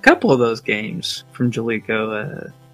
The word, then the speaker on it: Jaleco: How do you say that? Is that Jaleco or Jaleco? – Jaleco. – Jaleco. I like that even better.